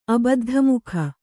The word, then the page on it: ಅಬದ್ಧಮುಖ: abaddhamukha